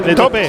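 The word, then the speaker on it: taupe: Tope. Se dice igual, es la misma palabra.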